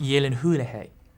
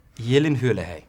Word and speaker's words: Gyllenhaal: It's pronounced Gyllenhaal. – Gyllenhaal.